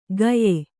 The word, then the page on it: ಗಯೆ: kannada